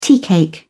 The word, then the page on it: teacake: British English